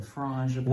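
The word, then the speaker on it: frangipane: Frangipan. – We're probably saying that wrong. – Frangipan. – We asked one of the team members there and cast members, and they said it exactly like we said it, so we figured they didn't really know.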